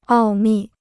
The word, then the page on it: 奥秘: ào mì